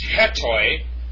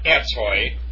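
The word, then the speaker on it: hetoi madzo (ἑτοιμάζω): So it's pronounced hetoi, not etoi. The rough breathing is with an H. Hetoi madzo. And you notice that the last letter is a zeta. And it's pronounced like with a DZ sound. And the correct pronunciation of I prepare is hetoi madzo.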